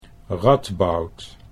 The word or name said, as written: Radboud